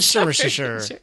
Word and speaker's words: Somersetshire: Somersetshire. No, that's it. Nailed it. – Nailed it. – We're going to Somersetshire. – We're going to have some Worcestershire sauce. – Worcestershire sauce and Somersetshire.